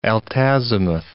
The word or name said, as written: altazimuth